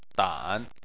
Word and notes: dan: 3

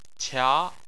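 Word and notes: qia: tone 2

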